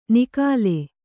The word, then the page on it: ನಿಖಾಲೆ: Kannada